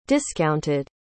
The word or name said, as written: discounted